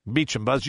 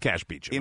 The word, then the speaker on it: Beauchamp: Even though it's spelled Beauchamp. – I know. No. That's actually the correct pronunciation.